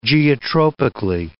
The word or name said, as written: geotropically